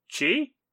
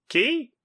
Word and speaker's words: chi: His crowd control does some decent damage, and big old strike tile when he's able to focus his chi? Ki? – I go with ki. – Chi? Okay. I mispronounce most things, so I'll just stick with chi.